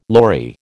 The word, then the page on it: lory: American English